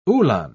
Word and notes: uhlan: British English

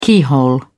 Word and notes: keyhole: British English

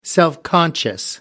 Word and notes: self conscious: American English

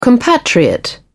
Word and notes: compatriot: British English

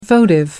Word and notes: votive: American English

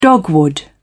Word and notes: dogwood: British English

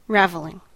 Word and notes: raveling: American English

